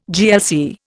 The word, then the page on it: GLC: Indian